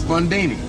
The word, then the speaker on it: Bundini: Bundini. Rhymes with Houdini, yeah, except that don't rhyme.